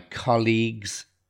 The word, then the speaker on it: colleagues: Because, so we have this word because my colleagues, so the stress on the word colleagues, and that it's spelt C-O-L-L-E-A-G-U-E-S, but the pronunciation is really important.